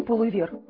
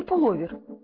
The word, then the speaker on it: пуловер: Захвати пуловер. Или пуловер. Как правильно? – Папа мне пуловер подарил. Все завидуют. – Да, он действительно красивый. Только, по-моему, нужно говорить пуловер. – Нет слова пуловер. Пуловер. Запомните. Пуловер. – Пуловер нужно произносить только так.